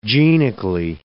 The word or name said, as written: genically